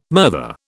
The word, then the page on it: murther: British English